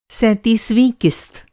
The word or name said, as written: सैंतीसवाँ